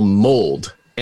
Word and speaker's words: mold: Koji is Japan's national mold. M-O-L-D, I did not mispronounce malt.